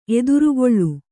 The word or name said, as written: ಎದಿರುಗೊಳ್ಳು